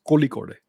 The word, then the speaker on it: Kozhikode: And this course will be imparted to various students from various countries by the Indian Institute of Management, Kozhikode. Calicut, Kozhikode. Kozhikode. I don't know how to say it. Yeah, it's Kozhikode.